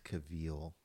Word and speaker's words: Cavill: um it's pronounced Cavill.